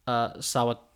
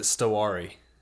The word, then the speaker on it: Sawatari: Or is it Stawari? Is nope. – it not? Okay. There, I think, Sawatari. It is Sawatari.